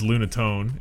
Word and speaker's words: Lunatone: You said Luna. It's Lunatone.